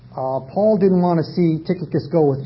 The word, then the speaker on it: Tychicus: And Paul had a very faithful brother, Tychicus. I think there are approximately 10 different ways of pronouncing that dear brother's name, but I'll say Tychicus.